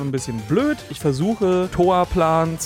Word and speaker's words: Toaplan: des Studios falsch aussprechen, weil mein Kopf immer automatisch aus Toaplan Taoplan macht. Einfach weil es phonetisch so viel besser klingt. Die Firma eigentlich wirklich traditionell im Japanischen heißt Toapuran, ne?